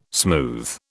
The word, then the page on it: smooth: British English